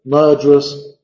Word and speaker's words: murderous: Entonces, perjured, murderous, es el mismo sonido. Es perjured, no es perjured, dos sílabas, y murderous, murderous, murderous, dos sílabas.